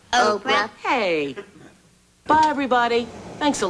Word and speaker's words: Oprah: My name is Oprah. – Oprah. – No, no. – Oprah. – Oprah. – O. Oprah. – O. Pra. O. – Cha. Like opera, but with a long O.